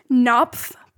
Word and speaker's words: Knopf: It is Knopf Publishing Group, K-N-O-P-F. – Knopf. – Knopf. Is it really? – It is a hard K, yeah.